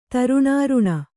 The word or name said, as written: ತರುಣಾರುಣ